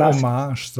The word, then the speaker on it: Homage: Homage oder Hommage oder wie auch immer sie es aussprechen soll. Also dieses. – Ich würde Hommage sagen.